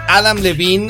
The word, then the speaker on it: Adam Levine: Con el, dicen las chicas y algunos chicos, el guapérrimo, guapísimo y sexy Adam Levine, Olivine, como se quiera llamar. ¿Cómo se pronuncia? Es Adam Levine.